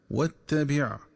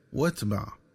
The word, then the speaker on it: واتبع: وكذلك ينبغي التنبه للنطق الصحيح للفظ واتبع، فيقرأ بتشديد التاء مفتوحة وكسر الباء مع حذف همزة الوصل، هكذا واتبع. ومن الخطأ قراءته بتسكين التاء وفتح الباء، هكذا واتبع.